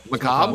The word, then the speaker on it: macabre: Macabre? – What is it? – Macabre. – It's the macabre. R-E is silent? – Yeah.